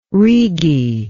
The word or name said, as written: Rigi